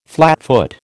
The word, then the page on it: flatfoot: American English